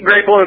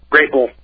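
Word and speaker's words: Grāpple: I was just going to say it's spelled like grapple, but they put a mark over the A so you know it's a long A. – Right, and they also say below that, they say grape-L, Grapele.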